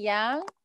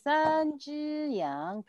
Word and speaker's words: 羊: 一只羊, okay, yang is what animal? Ba! Ba! Ba! Yang, repeat, yang.